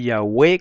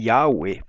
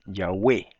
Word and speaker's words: Yahweh: Es Yeshua con acento en la U, es Yahweh con acento en la E. Aunque algunos dicen Yahweh, pero eso es por influencia americana, ¿verdad? Del inglés. Lo correcto en hebreo es Yahweh, Yeshua, Yahoshua, Moshe.